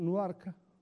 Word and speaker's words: Newark: a Newark, che diciamo, noi diciamo Newark, è la nostra pronuncia.